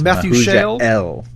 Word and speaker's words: Methuselah: If you usually, almost always in Hebrew, you stress the last syllable. So it's Methuselah.